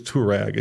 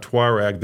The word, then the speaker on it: Touareg: There are a lot of people in the brand who don't, but it is Touareg. It's not Tuareg. The British say Tuareg. I'm not saying it's right or wrong.